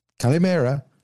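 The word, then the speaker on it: Calimera (Καλημέρα): Calimera. – Calimera. – Yeah. – Like calamara. Calamari. – Yep. Calimera.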